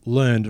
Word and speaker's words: learned: We have a learned guest on the show today. – It's pronounced learned.